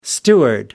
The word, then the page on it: steward: American English